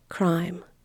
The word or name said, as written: crime